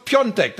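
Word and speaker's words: Piątek: Hier, guck mal, Krzysztof Piontek ist für mich auch nicht Piontek. Der schreibt sich ja Piatek von Hertha. Ja. Ähm, aber der, der spricht sich polnisch richtig ausgesprochen. Krzysztof Piontek, das ist Andrösen.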